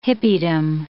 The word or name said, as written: hippiedom